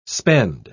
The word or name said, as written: spend